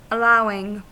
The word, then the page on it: allowing: American English; [əˈlaʊwɪŋ]